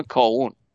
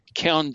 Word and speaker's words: kaon: بعدن یه چیز دیگه پیدا کردن تو دهه 70، کیان، ما تو فارسی فکر کنم میگن کاون یا کیان میگن، درستش تلفظش کیانه، K-A-O-N، کیان.